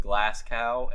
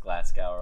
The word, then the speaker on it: Glasgow: So they're a social media marketing agency based in Glasgow and London. Probably pronounced Glasgow wrong.